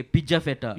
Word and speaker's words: Pigafetta: And also Pigeon... Pigafetta, you pronounce the G like a J. – Okay, Pigafetta.